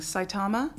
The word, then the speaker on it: Saitama: You were born in, correct me if I'm pronouncing this wrong, Saitama? – Yes.